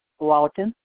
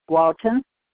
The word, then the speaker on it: Walton: Walton. – Are you saying Walton? – Walton. – Can you spell it for me? – W-A-L-T-O-N, Walton.